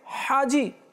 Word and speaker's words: হাজি: অনেকেই বলে হাজি হাজি, ছোট হা দিয়ে, কেঁচির মতো যেটা হা আছে এই হা ব্যবহার করে, অথচ হবে হাজি হাজি, যেটা হলক থেকে বের হয়, হলকের মাঝখান থেকে, হাজি যে হজ করেছে।